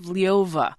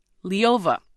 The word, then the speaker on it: Ljova: Not in the hands of Liova. That's spelled L-J-O-V-A. Liova.